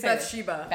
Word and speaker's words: Bathsheba: It's Bathsheba.